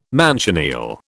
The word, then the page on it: manchineel: British English